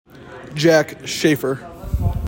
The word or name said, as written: JACK SHAFFER